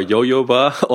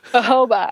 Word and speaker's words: jojoba: Is it jojoba or yo-yoba oil? – Oh, jojoba. – Jojoba, thank you.